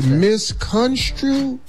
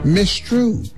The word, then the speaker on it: misconstrued: It's misconstrued? – Yes, sir. – Yeah. – What were you going to say? – Mistrue. – Who is that? – You need that middle syllable.